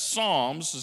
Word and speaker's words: Psalms: When he wrote the Psalms, please understand, the Psalms, even though it starts with a P, it's pronounced with an S, Psalms